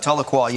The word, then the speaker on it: Tahlequah: We have media church members Rick and Darla from, I believe it's Tahlequah, Oklahoma. Forgive me for not pronouncing that. – Tahlequah, yeah.